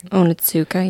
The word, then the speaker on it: Onitsuka: I FaceTime Colby while I was in London because we both are obsessed with this brand, Onitsuka Tiger. That's how you pronounce it, right? I think. – Onitsuka, yay.